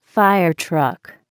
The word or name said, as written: fire truck